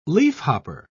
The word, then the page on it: leafhopper: British English